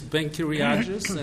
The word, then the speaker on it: Ben Kiriagis: The name is Ben Kiriagis, and if it sounds Greek to you, it's because it is.